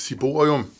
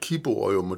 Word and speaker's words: ciborium: Vi kan også tale om alteret med det der tag over, som kaldes et ciborium. Nogle af de gamle kaldte det for et kiborium, og det var måske, fordi de syntes, at det skulle udtales med K.